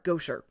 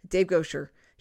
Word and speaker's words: Goucher: You know, you can go back and his name is spelled G-O-U-C-H-E-R, Gosher. Dave Gosher.